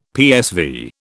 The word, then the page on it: PSV: British English